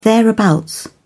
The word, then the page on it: thereabouts: British English